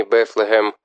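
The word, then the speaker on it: Bethlehem: Впоследствии слово «Вифлеем» в английском произношении «Бефлехем» преобразовалось в «Бедлам».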